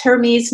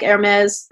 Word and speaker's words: Hermes: And that's Hermes, not like Hermes, the scarf, but spelled the same way.